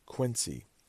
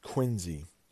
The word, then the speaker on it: Quincy: But Quincy, you might think, oh, it's Quincy, Quincy. It's actually pronounced Quincy. Now listen to this one. There's a city in Massachusetts. P-E-A-B-O-D-Y.